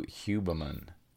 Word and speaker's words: Huberman: And then Andrew Huberman.